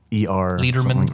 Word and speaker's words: Lederman: It's spelled differently. It's like LEDER. – Lederman. – Oh, yeah. Okay. – Like lead. Erman.